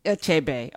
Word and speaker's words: Achebe: Achebe, okay.